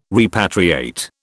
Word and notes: repatriate: British English

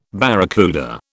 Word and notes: barracuda: British English

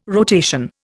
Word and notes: rotation: Indian

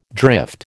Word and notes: drift: American English

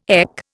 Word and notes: EC: Indian

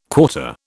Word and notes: quarter: British English

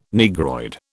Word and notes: Negroid: British English

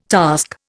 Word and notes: task: Indian

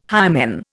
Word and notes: hymen: Indian

